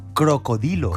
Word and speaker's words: cocodrilo: No, antes estábamos hablando de han salido cocodrilos. – Sí. – Bueno, pues el... ¿cómo se...? O sea, nosotros deberíamos pronunciar no cocodrilo, deberíamos pronunciarlo crocodilo. – Crocodilo. – Porque viene del latín crocodilus.